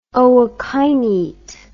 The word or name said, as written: ookinete